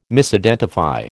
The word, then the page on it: Misidentify: American English